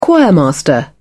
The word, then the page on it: choirmaster: British English